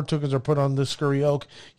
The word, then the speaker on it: Scurry Oak: Oh, Scurry Oak.